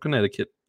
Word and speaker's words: Connecticut: Central Connecticut.